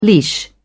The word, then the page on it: leash: British English